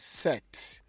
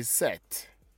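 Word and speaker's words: sept: And 6 is seas, S-I-X. S-I-X, that's C's, and that is 6, all right? And 7 is what? 7 is set, S-E-P-T, S-E-P-T, set. The P is silent. Everybody say set. And 8 is wit, wit, H-U-I-T, wit.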